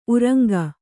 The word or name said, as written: ಉರಂಗ